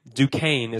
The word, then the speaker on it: Duquesne: So one, Duquesne is actually how it's said.